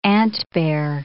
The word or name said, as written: antbear